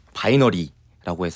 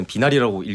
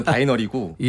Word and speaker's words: 바이너리: 곡 제목은 저희가 이제 바이너리라고 해서 사람들이 봤을 때는 비나리라고 읽히잖아요. – 아. – 네. 근데 원래는 바이너리고 예.